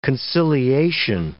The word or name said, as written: conciliation